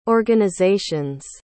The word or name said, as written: organizations